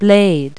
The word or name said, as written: blade